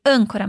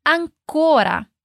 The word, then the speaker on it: ancora: Bene, atenção à pronúncia, nada de âncora, mas ancora.